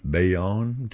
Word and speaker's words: Bayonne: We talked about Bayonne.